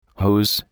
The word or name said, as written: hose